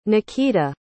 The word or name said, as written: nakita